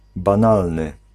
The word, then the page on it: enkel: /ˈɛŋ.kɛl/